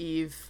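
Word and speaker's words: Yves: Yeah, Ives. I've always pronounced it Ives. – Eve.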